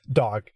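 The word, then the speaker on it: dog (דג): And then dog just means fish. And yes, in Hebrew, the word for fish is pronounced exactly like the English word for dog.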